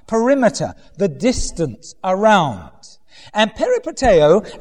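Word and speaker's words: peripateo (περιπατέω): It's the word in Greek, peripateo, right? That's not how modern Greeks would pronounce it, but peripateo.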